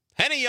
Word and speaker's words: Henny: He's a rhymes with Benny, not an old man. Henny Youngman.